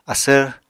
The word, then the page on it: asvr: Apatani